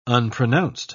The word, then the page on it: unpronounced: British English